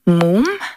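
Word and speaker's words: múm: a very new album by a band called Moom. It's spelled M-U-M.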